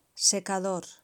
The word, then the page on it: secador: Spain Spanish